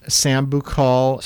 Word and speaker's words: Sambucol: I pronounced it Sambucol. – Sambucol. – S-A-M-B-U-C-O-L.